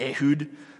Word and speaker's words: Ehud: Ehud, probably in Hebrew it's actually pronounced like Ehud. But no one talks like that. Ehud sounds better in English.